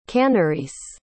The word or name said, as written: Kanarese